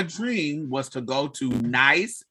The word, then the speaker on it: Nice: And he went to Nice, France. And so it was spelled like nice. So I would always call it nice. It's spelled like nice, but it's pronounced Nice.